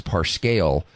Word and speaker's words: Parscale: And then, of course, you have the wisdom, or I even would use the word genius, of Trump's campaign manager, Brad, I think his name is Parscale, is the way it's pronounced, who decided that the strategic pathway to victory in the midterms of 2018 was to go after Senate seats.